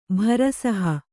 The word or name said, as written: ಭರಸಹ